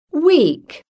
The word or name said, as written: weak